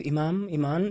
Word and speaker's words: Imam: En zijn broer was Iman. Imam, zeg ik dat goed? Imam, imam. Een, hele heilige, zeg maar.